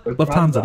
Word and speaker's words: Lufthansa: Lufthansa? Lufthansa. That's it. That's Lufthansa. It.